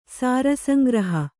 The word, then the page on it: ಸಾರಸಂಗ್ರಹಿ: sāra sangrahi